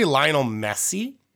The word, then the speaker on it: Lionel Messi: I'm going to say Lionel Messi.